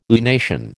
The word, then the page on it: lunation: American English